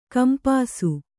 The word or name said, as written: ಕಂಪಾಸು